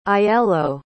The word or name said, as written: aiello